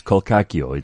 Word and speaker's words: Colcacchio: Yes, that is how you pronounce it. I'm very, very excited because these are... – Just throwing that disclaimer. – Well, because people don't know. Then they say to me, no, you pronounce it Colcacchio. You don't. All right. Trust me, it's Colcacchio. It's Italian.